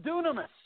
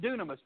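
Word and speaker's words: dunamis (δύναμις): The word is dunamis. You say dunamis. – Okay. The it starts out D Y N A. It's pronounced dunamis, but you know what, what we get out of that? We get the word dynamite out of that word.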